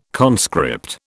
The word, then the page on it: conscript: British English